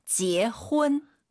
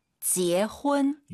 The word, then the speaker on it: 结婚: 제가 발음 틀릴 테니까 교정해 주세요. – 好的. – 지혜훈, 지혜훈. – 结婚. – 한번 더. – 结婚. – UN 사이에 어 들어가야 됩니다. 지혜훈. 한번 더 들어볼게요.